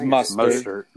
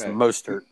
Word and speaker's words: Mostert: I mean, if Michael Thomas is back, and if my boy Raheem Mostert, I think is how you pronounce the last name. – It's Mostert. – I think it's Mostert.